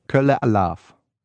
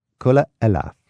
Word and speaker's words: Kölle Alaf: Kölle Alaf. Kölle Alaf.